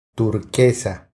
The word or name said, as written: Turquesa